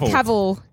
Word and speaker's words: Cavill: Is it Cavill? – I pronounce it right. Cavill. Cavill, yeah.